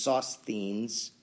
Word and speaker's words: Sosthenes: The correct pronunciation is Sosthenes. Paul is with Sosthenes, and Paul calls him, quote unquote, our brother.